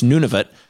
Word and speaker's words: Nunavut: It's pronounced Nunavut but it's spelled none of it basically, and it it became a thing on April first.